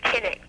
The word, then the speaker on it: Cynic: There's this ancient Greek philosopher called Diogenes, who was it's pronounced kinic, but it's the origin of the word cynic, and he was somebody who rejected the face value of everything.